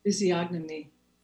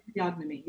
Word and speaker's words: physiognomy: and allied health sciences with physiognomy. Did I say it right? – Physiognomy. – Physiognomy. Okay. – Physiognomy. You've got it.